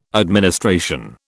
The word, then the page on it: administration: British English